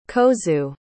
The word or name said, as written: kozue